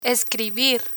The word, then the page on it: escribir: spanish